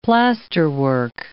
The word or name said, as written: plasterwork